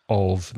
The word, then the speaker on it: of: If there's one F, of, then there's vibration in your throat.